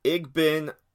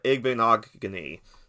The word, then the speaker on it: Igbinoghene: And that is Noah Igbinoghene. There you go. There's your pronunciation lesson. Noah Igbinoghene, the corner out of Auburn.